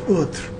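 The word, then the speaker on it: Urðr: It's more correctly pronounced urth, U-R-F-R, with where the F is that hard th. So that instead of being a fabric of, of lives, it's a fabric of life.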